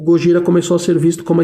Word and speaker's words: Gojira: Em 2001 a banda teve que mudar de nome para Gojira, que é como os japoneses pronunciam Godzilla.